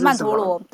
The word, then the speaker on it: 曼陀罗: 曼多拉曼多曼多拉吗？曼多拉，你们都怎么念？ – 就曼陀罗还是曼陀罗？对对对，就是那个大轮轴的图。